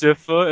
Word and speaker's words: Sypha: Pronounce Sypha as in syphilis? – Yeah, exactly.